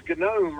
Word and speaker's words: gnome: And the word is gnome, right? – that is look how it should be pronounced. That's the way it looks. – Gnome. – Good day, gnome. – Oh, dear. – Yeah, G-N-O-M-E.